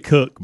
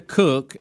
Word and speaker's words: Koch: Christina Cook and Jessica Mir. – Even though it's spelt Koch or Coke, it is Cook.